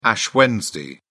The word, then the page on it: ash wednesday: British English